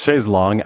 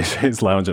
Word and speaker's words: chaise longue: It's chaise longue, actually, but I think because it sounds so weird in English, a lot of Americans say chaise lounge, and that's just become the accepted use.